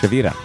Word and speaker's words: Shavira: Oh, you roll the R. – Oh, can you do that? – Shavira. – There you go. Yeah, I couldn't do that. – It's like Myra's Mira.